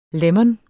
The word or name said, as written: lemon